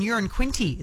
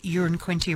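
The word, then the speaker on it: Uranquinty: It was Tambourine Bay Road, and I love this because Peter's texted in saying, I live in Urin Quinty. It's the same pronunciation as a number one, so it's not a big town. One could describe it as a wee place, and then a laughing emoji.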